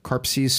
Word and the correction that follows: Kaposi: sarcoma. – Yeah, Kaposi sarcoma. – Kaposi sarcoma. Thank Sorry.